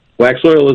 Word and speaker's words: Waxoyl: I never know if I have the pronunciation right. Waxoil? – Waxoil is right.